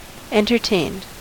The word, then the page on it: entertained: American English